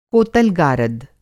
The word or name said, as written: कोतल-गारद